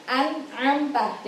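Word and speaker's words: An-am-ta (أنعمت): Di situ ada nun mati bertemu dengan ain. Ya kan? An-am-ta. Jadi kita bacanya harus an-am-ta. Gak boleh an-am-ta lah. Ada nun mati bertemu ain, berarti an-am-ta.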